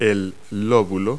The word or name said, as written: lobule